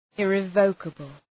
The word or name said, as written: irrevocable